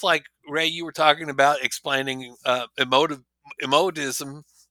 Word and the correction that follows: emotivism: How did you say it? – Emotivism. Emotivism.